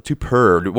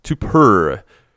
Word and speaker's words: Tupur: Tupper. I think I'm pronouncing it right. T-U-P-U-R.